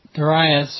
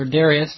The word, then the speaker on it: Darius: And of course, we would contrast Nebuchadnezzar's haughty attitude against the relatively humble expression that Darius, or Darius, depending on how you want to pronounce it, expression of his faith, the king of the Medes and Persians.